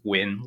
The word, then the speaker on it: Nguyen: It depends on how on who you're talking to and how they say it, but the way I say it for the American audience is win, like win or lose.